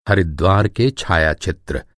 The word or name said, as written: हरिद्वार